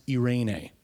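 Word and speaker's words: Irene (εἰρήνη): And in the New Testament, we get this word in Greek and it's pronounced Irene.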